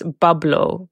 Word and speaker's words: Pablo: So actually in Arabic, Pablo is pronounced bablo because we don't have a P in Arabic.